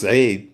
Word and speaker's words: Said: And so it's like, and plus our last name is, it's pronounced Said, but in English, it's spelled S-A-I-D, said, like almost as if I'm supposed to speak, you know, I'm in said.